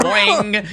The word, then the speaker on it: Boeing: Boing. You can also pronounce it boing.